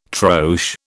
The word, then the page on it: troche: British English